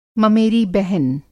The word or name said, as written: ममेरी बहन